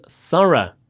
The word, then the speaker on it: thorough: And the O-U-G-H word there was thorough. T-H-O-R-O-U-G-H. It means very careful and exact. – And the pronunciation is different again. – Mm. – Listen to this one. Thorough. It's an sound right at the end of the word. Thorough. Thorough.